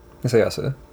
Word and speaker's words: Hisayasu: Wait, did I say his name wrong? – Hisayasu. – That's right, yeah.